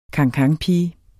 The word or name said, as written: cancanpige